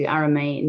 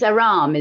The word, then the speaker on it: Arame: Now, today I'm really excited to talk to Arame Ndoye. I hope I'm saying that right. – Actually, no, it's Aram. No. The E is silent. – Oh, it's Aram, is it? – Aram, yes.